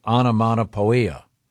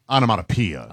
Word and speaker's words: onomatopoeia: But anyway, the description was, it was described as starting with explosive onomatopoeia. – Onomatopoeia. – Onomatopoeia. – It's spelled poia, but it's pia.